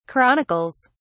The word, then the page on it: chronicles: British English